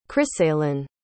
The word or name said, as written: krisalyn